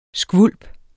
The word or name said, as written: skvulp